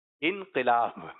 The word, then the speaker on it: انقلاب: تو کچھ لوگوں کا کہنا ہوتا ہے کیا آئے گا؟ انقلاب آئے گا. اور کچھ لوگ کہہ رہے ہیں انقلاب آئے گا. تو درست تلفظ ہے انقلاب.